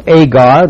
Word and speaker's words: Agar: For these are the two covenants, the one from Mount Sinai, which gendereth the bondage," which is Agar. This is the Greek pronunciation of Hagar.